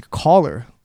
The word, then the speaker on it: Kollar: And people like think it's a Kollar, right? They think it's spelled C-O-L-L-A-R.